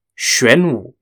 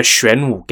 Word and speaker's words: Xuanwu: Xuanwu is spelt X-U-A-N-W-U, and in Chinese, this event is known as Xuanwu Men Zhi Bian.